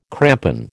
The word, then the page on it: crampon: American English